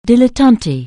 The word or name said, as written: dilettante